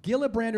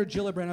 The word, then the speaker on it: Gillibrand: Joe Biden, Bernie Sanders, Cory Booker, Elizabeth Warren, Kamala Harris, Kirsten Gillibrand or Gillibrand. I've heard it pronounced both ways.